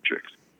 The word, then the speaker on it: Beatrice: And Beatrice is how they say her name in Italian.